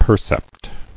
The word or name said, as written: Percept